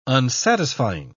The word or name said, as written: unsatisfying